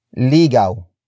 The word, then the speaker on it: ligaw: And that is ligaw.